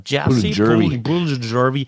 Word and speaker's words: Jesse Puljujärvi: Jesse Puljujärvi, helfen wir mal mit der Aussprache, Jesse Puljujärvi, der war jetzt für die Florida Panthers in dieser Saison nicht so wichtig, aber ist es eben für die Charlotte Checkers.